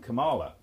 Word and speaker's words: Kamala: So you'd like to see Camilla? Is it Camilla? What's her name? Kamala, not Camilla.